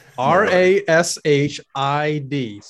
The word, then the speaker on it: Rashid: His first name, I'm pretty sure you pronounce it Sh- Rashid. R A S H I D. So Rashid. I think the ID is an E.